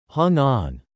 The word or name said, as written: hung on